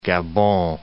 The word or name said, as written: Gabon